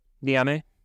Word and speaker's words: Niamey: You spent plenty of time in the capital of Niamey, right? Did I remember that right? – Yep. Niamey.